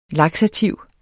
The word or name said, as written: laksativ